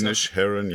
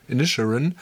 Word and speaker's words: Inisherin: Ich Inisherin, ja. Inisherin. – Inisherin. Ich habe es in der Aussprachedatenbank mit Inisherin gefunden.